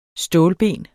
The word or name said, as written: stålben